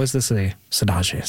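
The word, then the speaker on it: saudade: Okay, so Jesse, you were given Phases' theme of Sodage. Am I getting better at the pronunciation, by the way? – Sodaji, yeah. – Sodaji. He's not happy. It's Sodaji.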